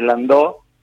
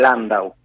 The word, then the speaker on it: Landau: Y bueno, siempre pasa por el apellido francés y en general me dicen muchas veces Landó, pero el apellido es Landau, hay también un pueblito alemán en la frontera con Francia, y el alemán también es AU, así que todo indicaría que la pronunciación en realidad es Landau, más que Landau, pero bueno, acá se pronuncia Landau.